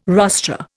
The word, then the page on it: rostra: Indian